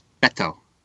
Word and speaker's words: Beto: But yeah, there's almost like a TH sound. It's like, it's Beto.